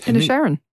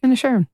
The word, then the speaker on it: Inisherin: Inisherin. – Inisherin? – Inisherin.